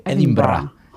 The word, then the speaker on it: Edinburgh: Edinburgh, es como se pronuncia, ¿no?